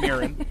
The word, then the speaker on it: Mirren: It's like Arden Marine. It's Arden Mirren. – Yeah. – It's Mirren.